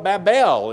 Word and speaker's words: Babel: But they call it Babel is the actual pronunciation of it here.